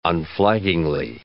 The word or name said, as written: unflaggingly